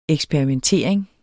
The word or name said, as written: eksperimentering